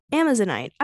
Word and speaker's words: Amazonite: How do you pronounce this? Amazonite. Wait, how do I pronounce this? Pronunciation. Amazonite. I was right.